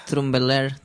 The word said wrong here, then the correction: Tumblr: Tumblr. Tumblr. Un Tumblr. – Ah, pues mira, he dicho una R de más.